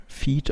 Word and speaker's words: fyyd: Mhm so also, wie du es gerade halt buchstabiert so hast, würde ich sagen, es wird Feed ausgesprochen.